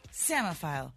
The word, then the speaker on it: psammophile: P-S-A-M-M-O. P-H-I-L-E, samophile? – That is correct. – That's correct. – Why is there a P before the S? The P is there for no reason.